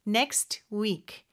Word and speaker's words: next: Next week인데 next의 요 T 발음은요. 사실 발음이 안 되는 경우가 많아요. 모음 이 아니라 자음 소리 사이에 낀 T나 D는 생략이 되는 경우가 있거든요. 그래서 여기서도 next week, next week 이렇게 발음할 수 있습니다.